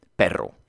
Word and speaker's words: perro: Men ordet perro har ju två r och därför så ska de betonas ordentligt med rullande r. Perro.